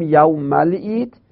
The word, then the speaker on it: یوم العید: پس در تلفظ ما میگیم یوم العید و اون الف رو میندازیم.